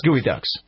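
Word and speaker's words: geoduck: Now, Gooey Ducks is spelled G-E-O, obviously, and then Ducks. It's not Gooey, it's G-E-O, but it's pronounced Gooey Ducks.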